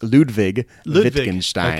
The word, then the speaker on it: Ludwig Wittgenstein: That's an excellent choice, but I have to correct your pronunciation. It's Ludwig Wittgenstein. – Okay, there we go. – Yep, in German the W's are pronounced like V's.